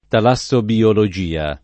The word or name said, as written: talassobiologia